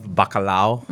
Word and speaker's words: bacalao: I love bacalao. – Mm-hmm. – Bacalao, it's called. – You're saying it right.